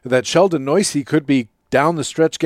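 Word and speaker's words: Neuse: Sheldon Noisy is apparently how it's pronounced.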